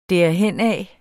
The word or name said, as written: derhenad